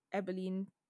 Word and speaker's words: Abilene: I got Abilene, I pronounced it right.